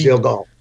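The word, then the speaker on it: Uziel Gal: So let's talk about, Uzel. Is that how you say it? Uzel? Uzi? – Uzel Gall.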